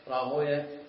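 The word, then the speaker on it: راهويه: ولذلك كان السلف من المحدثين يكرهون النطق بويه، وإنما يقولون سيبويا نفطويا زنجويا راهويا، فخلونا على طريقتهم.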